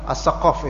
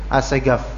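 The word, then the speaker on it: As-Saqqaf: As-Segaf, As-Sakof itu artinya As-Sakof, tapi dibacanya kalau di Indonesia seringkali As-Segaf.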